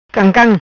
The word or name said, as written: Cam Cam